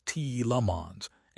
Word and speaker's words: Tielemans: They are instead prioritizing value-driven signings like Yori Tielemans, pronounced T.E. Lemons, and Andre Santos.